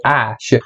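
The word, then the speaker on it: Æ: I mean, it's just X, the letter X, and the A-E is pronounced Ash.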